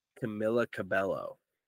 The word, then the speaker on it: Camila Cabello: I think it was Camila Cabello. I don't know how to say her name. Camila Cabello, that's right.